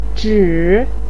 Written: zhi3

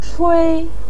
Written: chui1

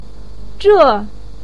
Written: zhe4

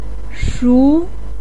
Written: shu2

